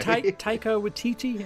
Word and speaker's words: Taika Waititi: Taika Waititi, am I pronouncing that right? – Mm-hmm.